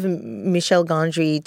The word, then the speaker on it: Michel Gondry: So it had a sort of Michel Gondry type of view of the viewer.